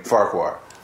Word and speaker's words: Farquhar: How do you say your last name? – I just say it like that. It's Regan Farquhar.